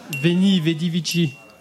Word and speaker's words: Veni, vidi, vici: En latin, veni, vici, vanci. – Veni, vedi, vici.